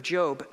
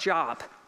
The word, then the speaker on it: Job: If you have your Bibles, I want you to turn to the book of Job, not Job. Turn to the book of Job, spelled the same.